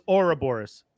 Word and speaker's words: Ouroboros: I thought it was Ouroboros. I thought Ouroboros. That's how you pronounce it, but sorry. I could be wrong. Listen to me on pronunciation. – No, you are right. I forgot. It is Ouroboros.